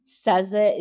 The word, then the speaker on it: Saze: I took out the O for people like you that can't pronounce my name. Saza is just fine. So Saza Ibrahim.